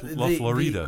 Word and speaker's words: La Florida: La Florida. Am I saying that right? – Yeah, it's close. – Okay, Florida. – Yeah, La Florida. – The accento's over the I.